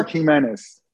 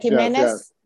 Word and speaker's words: Gimenez: Gimenez. Did I say it right? Yeah. – It's so, Kirk Jimenez. – Kirk Jimenez? – Yes. It's Jimenez, but with a G.